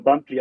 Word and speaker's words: Bumpli: Es wird Bumpli geschrieben, also Bumpli, ne? – Genau, und Bumpli ausgesprochen. Also genau.